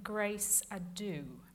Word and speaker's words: Grace Adieu: Not, I think, a terribly likely English place name, but being an English place name, it ought to have been anglicised. But I pronounce it as The Ladies of Grace Adieu, which is completely wrong, so don't follow what I do, because I say it wrong.